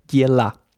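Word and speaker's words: Diela (дело): Und zwar das Wort für Sache oder auch Angelegenheit heißt Dielo. Mhm. Die meisten russischen Akzente würden ein unbetontes O wie ein A sprechen. Das spricht man aus Diela.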